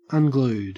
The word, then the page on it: unglued: Australian English